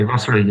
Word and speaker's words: Vaslui: Vaslui, sorry if I pronounce it wrongly. How do you pronounce it? – It's okay, Vaslui, yeah.